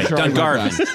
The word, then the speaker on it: Dungarvan: Conan, how do you say this? Dungarvan. – You barbarian. Yeah. – Yeah. I'm sorry. – It's okay. Dungarvan.